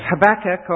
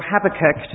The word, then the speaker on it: Habakkuk: And I'd like you to turn to the book of Habakkuk, or Habakkuk, depending how you say it.